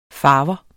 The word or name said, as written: farver